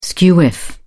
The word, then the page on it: skew-whiff: British English